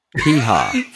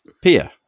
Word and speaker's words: Piha: You came up with like Piha, Fred. You pronounce Pia. It's got an H in it. P-I-H-A. You're like Pia Beach.